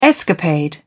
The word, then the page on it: escapade: British English